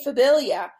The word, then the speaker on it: Phabillia: They couldn't say Phabillia.